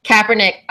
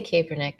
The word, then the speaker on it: Kaepernick: And also, side note, did I pronounce Colin's last name correctly? Because I'm always... – Kaepernick. – I always say Kaepernick. I suck.